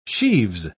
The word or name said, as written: sheaves